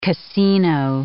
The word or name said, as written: casino